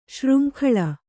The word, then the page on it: ಶೃಂಖಲ: kannada